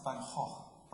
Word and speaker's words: Van Gogh: Me and Pete went to the Van Gogh Museum, which is pronounced Van Gogh, right? Yeah, thanks.